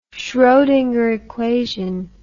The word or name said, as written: schrodinger equation